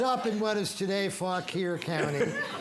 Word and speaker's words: Fauquier: Fauquier.